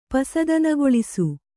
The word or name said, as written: ಪಸದನಗೊಳಿಸು